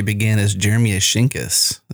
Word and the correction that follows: Ashkenas: since we did not have pronounced his last name. And then we learned it's Ashkenaz.